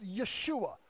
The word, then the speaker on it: Yeshua: It would have been, in Hebrew, is pronounced Yeshua, right?